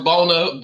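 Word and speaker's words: Buona: Um, so here's my thing, like, you know, I had Buena beef one time, right? Did I say it? How, how you say it? – I think it's pronounced Bona. Bona.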